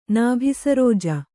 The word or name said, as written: ನಾಭಿಸರೋಜ